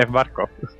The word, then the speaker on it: Schwarzkopf: aquí tenemos también a un señor llamado Norman Schwarzkopf. ¿Lo he dicho bien? – Sí, Norman Schwarzkopf.